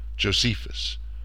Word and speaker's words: Josephus: But we will be sticking with the most commonly heard pronunciation for his name, Josephus, giving the E in his name the long, hard E in English.